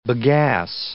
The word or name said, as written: bagasse